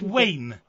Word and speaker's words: Wayne: Hello, my name is Wayne.